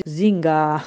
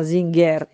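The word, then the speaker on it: Singer: Max si dice Zinga, sarebbe un po' con ER che non si pronuncia, ma Zinger in italiano, come in inglese, solo che la S si legge come Z, perché all'inizio delle parole in tedesco la S è sempre Z.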